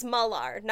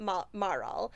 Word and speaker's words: Malar: Okay, so her name is Malar, not Maral. At some point I messed up the consonants and I started spelling it in the wrong order.